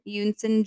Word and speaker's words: Eunsoon: In June of 2002, Terry's current girlfriend, and I'm so sorry if I say her name wrong, it's spelled E-U-N-S-O-O-N. Yunson? – Sounds right. – Yunson Jun. She was a chemist from California. – The N is silent. It's like Yunson.